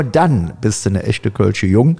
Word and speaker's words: Kölsche Jung: Richtig, René, du hast es falsch ausgesprochen. Es ist der echte Kölsche Jung und für alle Hörer,